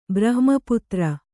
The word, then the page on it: ಬ್ರಹ್ಮಪುತ್ರ: Kannada